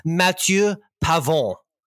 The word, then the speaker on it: Matthieu Pavon: And let me francophone it up for the people who are offended by your Americanized pronunciation. It's Mathieu Pavon, who is the winner at the Farmers Insurance Open last weekend.